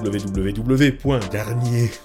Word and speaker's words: Garnier: Et puis retrouvez toutes les informations sur wwwgarnier euh, comme ça se prononce, comme l'opéra, comme les shampoings,